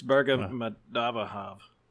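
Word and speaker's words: Berdimuhamedow: Your guy, Birdie Mohamedow? – No, that's you didn't say it right. It's Berga Madavahav.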